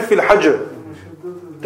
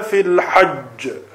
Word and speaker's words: hajj: If you say fil hajr, then that means you're pronouncing only one jim. You've got to pronounce two jims.